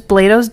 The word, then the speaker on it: Blados: It's spelled like Blodos, so it's like Blowdoes, but it's Blados Donut Shop.